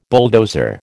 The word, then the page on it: bulldozer: American English